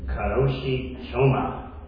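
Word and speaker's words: Kőrösi Csoma: I was taught how to say his name. Karoshi Choma. He visited India and studied languages and literature there.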